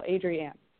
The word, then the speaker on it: Adrienne: My mom specifically will pronounce each syllable, Adrienne.